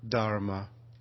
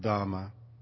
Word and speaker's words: dharma: Maybe she was thinking, in fact, of the root meaning of the Buddhist word dharma, sometimes pronounced dhamma, which I rendered in the Buddhist scripture you heard this morning with the phrase, what we hold dear.